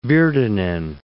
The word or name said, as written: Virtanen